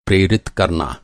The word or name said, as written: प्रेरित